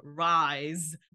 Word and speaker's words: RIIZE: We're starting with the new SM boy group that just recently got announced, which they are called RIZE. And it's spelled more like R-I-I-Z-E. And it's supposed to stand for Rise and Realize.